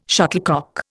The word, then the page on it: shuttlecock: Indian